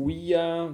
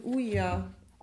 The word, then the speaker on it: Ouija: Das heißt nämlich vom französischen Ja und dem deutschen Ja. Daher kommt das. Oui, ja.